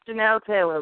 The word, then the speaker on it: Janell Taylor: Yeah, it's Janell Taylor, but everybody calls me Blondie.